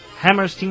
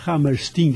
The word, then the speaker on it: Hammerstein: O inglês tem a tendência a pronunciar esse Stein, o EI, como I. É. Né? E o americano tem a tendência a pronunciar o EI como I. – É. – Então a pronúncia para os americanos é Hammerstein. Mas a pronúncia para os ingleses é Hammerstein, quer dizer, na verdade esse nome vem do alemão, Hammerstein.